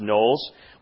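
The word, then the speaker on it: Knollys: And his name should be pronounced Knowles.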